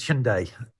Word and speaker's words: Hyundai: The Korean pronunciation is Hyundai.